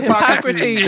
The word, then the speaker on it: Hippocrates: Hippocrates. – There you go. Hippocrates and that, so it's like, so we're just like strong in our side, you know, as well. Like, so that, no, it's not Hippocrates,